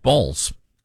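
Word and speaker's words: Baals: Because it's a B-A-A-L-S is the actual spelling, but the pronunciation is, in fact, Balls.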